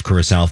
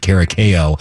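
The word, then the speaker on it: Curaçao: I know it's Curacao. Thank you for the correction. We pronounced it Caracao because honestly, I thought Kayla was joking.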